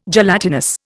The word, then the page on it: gelatinous: Indian